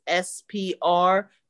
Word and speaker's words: Spruill: My last name is Spruill.